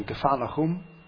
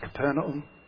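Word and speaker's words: Kfar Nahum: Secondly, resettlement in Kfar Lahum, which we normally pronounce as Capernaum.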